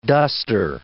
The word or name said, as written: duster